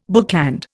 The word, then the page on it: bookland: Indian